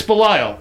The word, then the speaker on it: Belial: Yeah, I looked it up, and it's Belial.